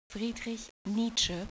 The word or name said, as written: Nietzsche